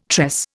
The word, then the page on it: tress: Indian